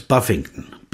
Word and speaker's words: Buffington: Charles Buffington. B-U-F...